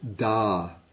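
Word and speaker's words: dā: But the root for to give is da, simply D long A.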